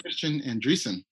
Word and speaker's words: Christein Andresen: So we have Mr. Christein Andresen.